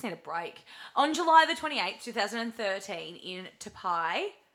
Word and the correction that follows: Taipei: Taipei? – I'm having an absolute, how do you say it? – Taipei. – Taipei.